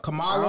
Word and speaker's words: Kamala: Yeah, Kamala.